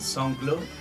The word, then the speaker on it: SoundCloud: Después tenemos las canciones subidas en SoundCloud, ¿no?